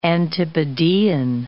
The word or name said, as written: antipodean